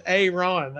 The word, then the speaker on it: Ayron: Yours is Ayron. They want to say. – Yeah, that's right. – They say Aaron. – Yeah, that's right. It's phonetically, it's spelled how it's said, A-Y-R-O-N, you know.